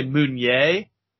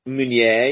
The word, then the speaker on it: Meunier: It looks like it should be pronounced manure, which can't be right. – I was going to say Meunier. – Meunier, yeah.